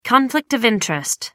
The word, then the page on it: conflict of interest: British English